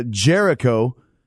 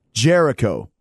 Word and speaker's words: Jericho: Is this the real Chris Jeringo? – It's actually Jericho, but I know you're from Italy, and sometimes you have a problem pronouncing names, but Jericho.